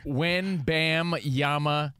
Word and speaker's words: Wembanyama: When Bam Yama, it's spelled exactly how it sounds.